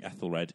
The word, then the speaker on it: Ethelred: I'm very certain, though, that our King Ethriald the Unready audience is definitely going to really pick up over time. – Yeah, but you've lost them by pronouncing Ethelred Ethriald.